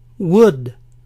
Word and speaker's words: would: Si quiero decir yo comería, todo lo que tengo que hacer es añadir en medio esta palabra que se pronuncia sin la L. Would, would.